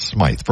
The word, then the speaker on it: Smyth: Yes, it is pronounced Smythe from Scandal, you know, the warrior.